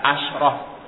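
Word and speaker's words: asyrah (عشرة): Dan dia disukunkan asyrah. Walaupun ada lugah lain bacanya asyrah dengan difathahkan, tapi yang masyhur dia disukunkan asyrah dengan disukunkan.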